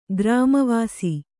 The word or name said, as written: ಗ್ರಾಮವಾಸಿ